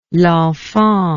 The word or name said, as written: L'Enfant